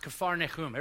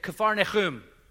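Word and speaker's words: Kfar Nahum: It's actually, it would have been back then pronounced Kfar-Nechum. Everyone say Kfar-Nechum. Bless you. There is pollen count is off the charts. There is, so this word, this basically means the village of Nahum.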